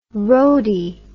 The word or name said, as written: roady